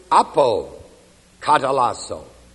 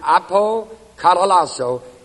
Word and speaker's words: apokatallasso: Another word is the word apokatalasso. A-P-O-K-A-T-A-L-L-A-S-S-O. Long O. That again is reconciled. And this particular word, apokatalasso, is reserved and used only for the revelation of the mystery.